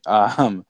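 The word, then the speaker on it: Bitonio: He already had Joe Thomas, but then he brought in, Joe Bottino. That's how you say his name, right? – Bottino.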